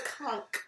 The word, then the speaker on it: conch: I know y'all pronounce it conk.